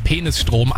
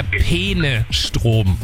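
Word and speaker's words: Peenestrom: Und es ist, also ich bin mir sicher, sie spricht es absichtlich so aus, weil sie sagt ja eindeutig Penisstrom, aber es geht um den Fluss Peene und es ist der Penestrom.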